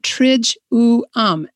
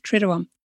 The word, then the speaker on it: Triduum: And then she spells it out, Trij-u-um. And when I read that, I realized I've been mispronouncing it my whole life, Triduum.